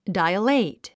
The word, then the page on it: dilate: dailéit; ダイレイトゥ